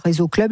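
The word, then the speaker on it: RESO Club: Sur les réseaux, c'est réseau comme les réseaux sociaux ou c'est comment? – Alors, ça s'écrit? – Je vais vous l'épeler. Alors, R-E-S-O et Club, C-L-U-B. – Tout simplement.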